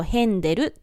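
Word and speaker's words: Händel: ちなみにヘンデルの元々のスペルは HA の A の上にウムラウトと呼ばれる 2 つの点がついていまして、発音としてはあではなくえが近く、つまり日本語ですとヘンデルという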